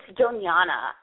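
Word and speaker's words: Doñana: It's Donana.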